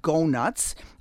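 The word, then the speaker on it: Gough Nuts: One that I like is one that's called Go Nuts. It's spelled G-O-U-G-H, nuts, like donuts, but Go Nuts.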